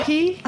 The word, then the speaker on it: Riahi: Riahi? – Oh, good job. – Riahi. I was going to Instagram Naz Riahi.